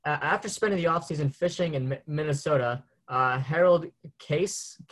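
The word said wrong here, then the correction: Kaese: how do I pronounce that? – Kaza.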